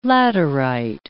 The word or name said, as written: laterite